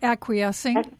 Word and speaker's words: acquiescing: Am I saying it right? – Acquiescing? Yes.